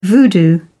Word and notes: voodoo: British English

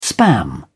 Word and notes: spam: British English

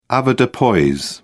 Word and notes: avoirdupois: British English

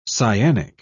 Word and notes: cyanic: British English

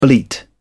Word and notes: bleat: British English

